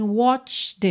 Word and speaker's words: watched: o E no caso é mudo, mas o D tem que aparecer, então a gente tem watched it, tá, watched it burn.